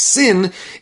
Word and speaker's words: sin: And there's a dot on the left side of the shin. That's not a shin anymore. That's called a sin. It's pronounced sin instead of shin.